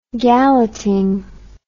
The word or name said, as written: galleting